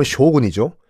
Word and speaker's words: 쇼군: 장군. 일본식 발음은 쇼군이죠. 어, 쇼군이 일본을 실질적으로 통치를 했고 덴노는 그냥 뭐 허수아비 사, 장 정도.